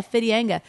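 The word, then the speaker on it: Whitianga: It's actually Whittianga. But now the Maori people are taking back the right pronunciation. So it's actually rightly pronounced. – That's a word. – Good on them. Yeah, Fittianga.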